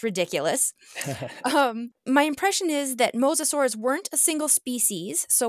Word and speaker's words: mosasaurs: Your research so far in your career has primarily focused on mosasaurs. Did I pronounce that correctly? – That is correct.